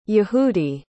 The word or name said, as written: yehudi